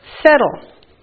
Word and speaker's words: settle: Settle. The T is silent.